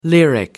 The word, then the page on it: lyric: American English